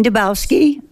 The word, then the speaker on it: Dubowski: Hello, my name is Eileen Dabowski. It's spelled D-U-B-O-W-S-K-I, and I live in Salcha.